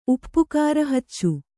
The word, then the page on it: ಉಪ್ಪುಕಾರಹಚ್ಚು: Kannada